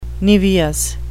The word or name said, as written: niveous